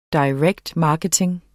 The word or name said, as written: marketing